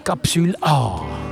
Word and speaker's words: Capsule A: Capsule A. – In der Zwischenzeit haben wir herausgefunden, dass es die französische Aussprache ist, die richtige ist.